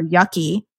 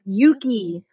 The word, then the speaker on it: Yuki: Her name's Yuki, and the Facebook people told me to name her Yucky. I was like, no, it's Yuki. like with double long O sound.